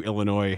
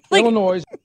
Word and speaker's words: Illinois: Here's the best part about all of this, is that in Chicago, where we put S's on the end of everything, we do not pronounce the S on the actual word with the S at the end. – Well, there's a law of conservation of the S. That's what it is. If we don't pronounce the S in Illinois, we have to put it on the end of Jewel.